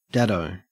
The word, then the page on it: dado: Australian English